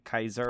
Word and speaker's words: Caesar: It's kind of like when I found out that there is no C in the Roman language, like Roman Latin language, so that like Caesar would have probably been pronounced like Kaiser.